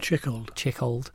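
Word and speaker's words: Tschichold: So one of your early influences, as well as Abram Games, was actually Jan Tichold, is that how you pronounce it? – Chickold.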